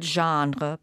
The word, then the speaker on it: genre: poi dice "such an old genre", perché questa è una delle parole che gli americani prendono in prestito dai francesi, e in realtà lo pronunciano alla francese, ma è genere, cioè il genere vampiresco è molto antico.